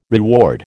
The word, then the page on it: reward: American English